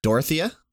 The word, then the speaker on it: Dorothea: Dorothea? – Yeah. – Yeah, it looks like Dorothy, but it's Dorothea is how they pronounce Dorothea.